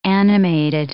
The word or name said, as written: animated